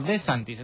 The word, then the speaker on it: De Santis: ¿Es así, De Santis? ¿Saben que sí. No lo pronuncio? ¿Es De Santis? – No, De Santis.